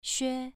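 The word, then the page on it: xue: tone 2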